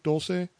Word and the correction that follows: Dolce: Oh, Dolce, yeah, okay. excuse me, Mike Dolce. I'm probably saying it wrong and uh,